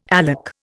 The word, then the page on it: alec: Indian